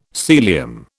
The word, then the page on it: Sealyham: British English